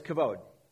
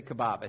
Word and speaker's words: kavod: In Hebrew, the word for glory is kavod. It's often mispronounced. I hear people say kebab. I said, no, kebab's what you get when you go to a Greek restaurant.